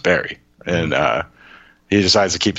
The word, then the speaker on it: Barre: Okay, so it's Barre. – Okay. – So you have that in Pennsylvania, right? How's it pronounced there? – It's Wilkes-Barre.